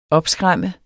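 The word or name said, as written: opskræm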